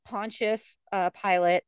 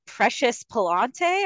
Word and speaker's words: Pontius Pilate: like when he's with Pontius Pilate, I keep saying it because I just learned how to say it properly, because I kept saying like I kept forgetting and not saying it right. I was like, what, Precious Palante or what is it?